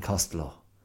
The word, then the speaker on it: Costello: Abbott and Costello, or if you want to go for the Irish pronunciation, Abbott and Costello.